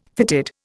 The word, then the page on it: fitted: Indian